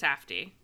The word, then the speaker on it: Safdie: Safdie or Safdie? – I think it's Safdie. I think Safdie. It's the Safdie brothers, yeah.